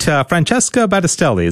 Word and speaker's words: Francesca Battistelli: Francesca Battistelli. Did I pronounce that right? Francesca Battistelli, is that right? – Yes, perfect.